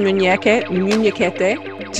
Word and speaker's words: Munhequete: Okay, welcome, Carmen Munhequete, to the Eco Interviews.